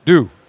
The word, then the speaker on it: doux: Now, the thing, deux, much better. – Deux. Got to make sure, just like the morning dew. Same thing. And it's spelled D-O-U-X.